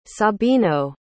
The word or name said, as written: sabino